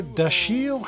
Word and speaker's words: Dashiell: I'm your host, Ricky Lee Grove, and in today's episode, we'll be looking at the author Dashiell Hammett and the origins of the hard-boiled paperback. By the way, I correctly pronounced Hammett's middle name.